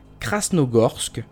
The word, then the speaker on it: Krasnogorsk: Et finalement, première hypothèse peut-être plus crédible, ces tests ont porté les soupçons sur la ville fantôme de, et attention à la prononciation, Krasnogorsk. – Bravo.